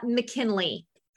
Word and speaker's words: McKinley: So Charla McKinley, like the okay.